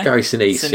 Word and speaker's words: Sinise: Is that how you pronounce it? – Yeah. – Gary Sinise.